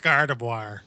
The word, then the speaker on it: Gardevoir: It's pronounced Gardevoir. – Is it Gardevoir? – The glasses so hard. – It is, actually, yeah. – I always thought it was, like, Endeavor, like Gardevoir.